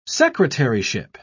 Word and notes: secretaryship: British English

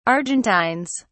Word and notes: argentines: British English

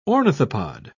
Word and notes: ornithopod: British English

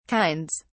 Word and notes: kinds: British English

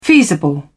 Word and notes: feasible: British English

